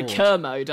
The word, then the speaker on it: Kermode: Well, I want to talk about Commode is where I went. – Oh, of course. But, sorry, Kermode. I always say it wrong. – Not Commode, as in the other word for a toilet.